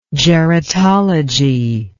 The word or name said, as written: geratology